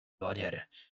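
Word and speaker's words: Mjölnir: É Mjolnir.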